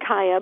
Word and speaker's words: Kaya: I pronounce it Kaya.